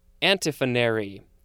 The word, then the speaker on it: antiphonary: And now we have antiphonary. That's how it's pronounced. A-N-T-I-P-H-O-N-A-R-Y.